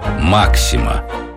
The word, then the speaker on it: Максима: Во-вторых, уж очень это прозвище походило на известную пулеметную систему «Максима». Ударение, кстати, нужно ставить именно так.